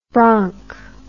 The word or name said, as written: bronch